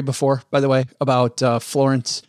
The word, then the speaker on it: Florence: I think it's pronounced, if you've driven down I-75, you know, it's pronounced Florence, y'all.